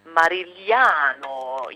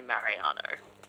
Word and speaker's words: Marigliano: But from the future, I can tell you that my last name is spelt Marigliano. It is Italian, so it's meant to be Marigliano. You know, you've got to really say it with that accent, but I totally cheat and I just say Mariano.